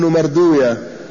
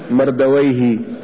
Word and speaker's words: مردويه: قال: وحديث أنس قال أخرجه أيضا البيهقي وابن مردوية هكذا يعني ينطقها المحدثون، وأما علماء اللغة فيقولون مردويه، كل ما جاء على هذا الرسم يقولون ما ختم بويه كسيب ويه وراه ويه ونفط ويه ومرد ويه، أما المحدثون فينطقون هذه الأسماء بغير ما اشتهر عند المحدثين، ولذلك يقولون ابن مردوية.